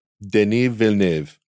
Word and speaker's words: Denis Villeneuve: This is a problem that I also have with Denis Villeneuve.